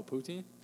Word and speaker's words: poutine: I tried to make poutine for everybody. You know, you guys know what poutine is? – Oh, poutine? – Poutine.